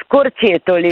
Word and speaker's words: Scorcetoli: Come si pronuncia? – Allora, si pronuncia Scorcetoli.